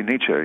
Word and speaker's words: Nietzsche: I say it the way you say it. – Oh. – I say Nietzsche, yeah.